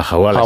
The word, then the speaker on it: hawala: ¿Y el Ouahala? Creo que se dice así. – Sí, la Hawala.